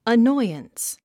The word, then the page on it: annoyance: ənɔ́iəns; アノイアンス